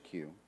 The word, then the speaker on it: queue: Now, this is the British cue. So, kids, it's spelled Q-U-E-U-E. And it just so happens in this word that the U and the E and the U and the E are silent.